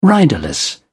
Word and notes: riderless: British English

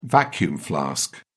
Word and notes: vacuum-flask: British English

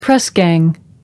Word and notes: press gang: American English

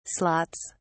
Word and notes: slots: British English

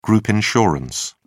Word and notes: group insurance: British English

